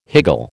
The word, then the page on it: higgle: American English